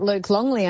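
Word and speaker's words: Luc Longley: And he's one of those rare Lukes where he's an LUC, isn't he, with a long U? – Yeah. Yes. Yeah, yes, which... Yeah. Which I didn't know a lot about Luke Longley until they'd said that he'd moved in, and to them he was just neighbour Luke.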